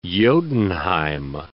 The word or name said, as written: Jotunheim